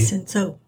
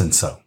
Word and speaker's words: Cinsault: Now, as you mentioned, Pinotage is a Pinot Noir crossed with a Cinsault. – Cinsault. – Cinsault. – And that's how it's pronounced, Cinsault.